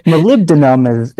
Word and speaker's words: molybdenum: Molybdenum is how I've heard it.